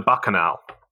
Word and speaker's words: Bacchanal: The Bacchanal. – That's right. And the Bacchanal was a very famous restaurant at Caesars. And it only closed down a few years ago in Atlantic City, the Bacchanal restaurant.